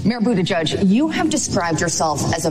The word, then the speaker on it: Buttigieg: He started out this whole campaign telling people exactly how they were to pronounce his name, and it is Buttigieg, Buttigieg. And one of the memes, you know, on social media is to call him Buttigieg, and I think Nora O'Donnell just did that.